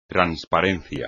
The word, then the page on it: transparencia: espagnol